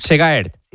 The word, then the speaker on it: Segaert: Segaert.